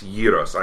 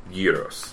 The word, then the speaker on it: gyros: But the Greek pronunciation, gyros.